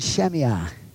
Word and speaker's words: Shammai: Hillel and Shammai, I believe is how you say his name.